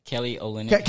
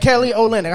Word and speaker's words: Kelly Olynyk: You know, yeah, well, how you pronounce my boy name? Kyle Linick? – Kelly Olynyk. – Kelly Olynyk. I said Kyle Linick. I'm putting it all together. Yeah, Kelly Olynyk.